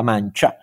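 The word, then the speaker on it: Mancha: Mancia, che in spagnolo si scrive con CH, ovviamente, però si dice mancia,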